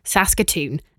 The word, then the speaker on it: Saskatoon: Chris sent me a quick email that said, "No need to worry, you pronounced it as it should be, Saskatoon, and you got the province name correct too.